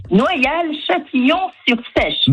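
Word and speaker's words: Noyal-Châtillon-sur-Seiche: Noyal-Châtillon-sur-Sèche.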